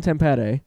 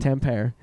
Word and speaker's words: Tampere: He is from Tampere, which is how you say that. – He's from where you just said he's from. – That's right. – He's from Finland. – Tampere, as the Americans say it.